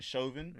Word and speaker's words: Chauvin: I don't know if I'm pronouncing his name right. – Don't care. – Chauvin. – Is it Chauvin? – Yeah. Don't care.